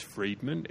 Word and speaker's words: Fridman: So it's a guy called James, I assume it's pronounced Friedman, F-R-I-D-M-A-N.